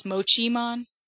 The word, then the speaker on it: Mochimon: I don't know why those, like, Motomon, is it Mochimon? – Because you see, yes. I think in Japanese it's Mochimon. – Oh. – Because it looks like Mochi.